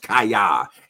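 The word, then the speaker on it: Chaya: And now it's spelled H-A-Y-A, but it's pronounced Kaya.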